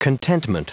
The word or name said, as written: contentment